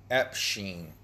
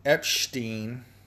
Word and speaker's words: Epshteyn: It's it's spelled E P S H T E Y N. Epstein. Epstein. Not to be confused with Jeffrey Epstein, okay?